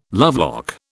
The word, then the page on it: lovelock: British English